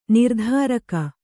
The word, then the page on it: ನಿರ್ಧಾರಕ: nirdhāraka